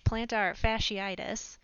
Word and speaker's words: fasciitis: Well, it's actually pronounced plantar fasciitis, but